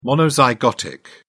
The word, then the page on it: monozygotic: British English